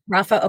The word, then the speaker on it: Rafah: We can't have two thirds of a victory, because Hamas will reconstitute itself with these four battalions in Rafah. Did I pronounce that correct? Rafah? – Rafah.